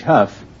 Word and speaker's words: Hough: No, Sue is S-U-E, like Sue St. Marie, which actually is spelled S-I-O-U-X. You say you pronounce H-O-U-G-H, Huff. How do you pronounce D-O-U-G-H? – That's dough.